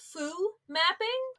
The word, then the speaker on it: Foo Mapping: You have a new book called Faux Mapping. Is that how you say it? No, it's actually called Foo Mapping.